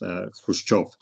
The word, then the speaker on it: Khrushchev: Well, I think the standard English transliteration or the way of writing it is Khrushchev. However, in Russian, it should be pronounced Khrushchev.